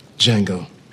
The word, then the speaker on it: Django: "Django." – "Can you spell it?" – D-J-A-N-G-O. The D is silent.